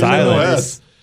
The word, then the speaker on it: Illinois: There's an S in there. You pronounce it Illinois.